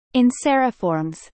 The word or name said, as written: Anseriformes